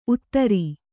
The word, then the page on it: ಊದರೆ: Kannada